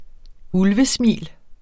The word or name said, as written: ulvesmil